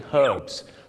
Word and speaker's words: herbs: ชาวอังกฤษก็จะออกเสียง herbs ตัว h หอนุฮูกชัดเจนมากแต่ชาวอเมริกาเยอะมากครับออกเสียงว่า herbs ไม่มีตัวหอนุฮูกกลายเป็นอ่างสมุนไพร herbs กับ herbs